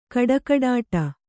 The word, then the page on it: ಖಡಖಡಾಟ: khaḍakhaḍāṭa